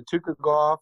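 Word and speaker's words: Tukhugov: Zubera Tukugov. I think I said that right. – Tukugov. – Tukugov. Yeah.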